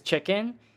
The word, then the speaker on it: Chikin: So my legal middle name is Chicken. It's spelled C-H-I-K-I-N, and it's actually my Chinese name written English phonetically.